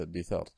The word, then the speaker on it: الدثار: والمدثر اسم فاعل من تدثر إذا لبس الدثار، فأصله المتدثر، أدغمت التاء في الدال لتقاربهما في النطق كما وقع في فعل الدعا، والدثار بكسر الدال الثوب الذي يلبس فوق الثوب الذي يلبس مباشرا للجسد الذي يسمى شعارا.